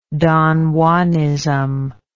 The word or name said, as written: don juanism